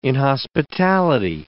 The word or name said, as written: inhospitality